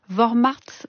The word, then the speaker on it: Vormärz: Vous avez donné le terme allemand, Isabelle Garot, c'est Vormärz. Vormärz.